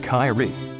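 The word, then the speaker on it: Kairi: Kyrie, her name is pronounced Kyrie, the one to see.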